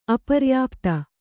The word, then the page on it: ಅಪರ್ಯಾಪ್ತ: aparyāpta